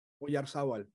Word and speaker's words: Oyarzabal: Mikel Oyarzabal. – Oyarzabal, yeah. – You got to take the Basque for that pronunciation.